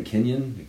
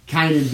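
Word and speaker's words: Kaynan: That's super interesting because, I mean, old boy, how do you say his name, Kenyon? – I think it's Kanan because it's K-A-Y-N-A-N.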